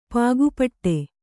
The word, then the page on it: ಪಾಗುಪಟ್ಟೆ: kannada